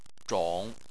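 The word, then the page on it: zhong: tone 3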